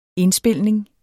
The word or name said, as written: indspilning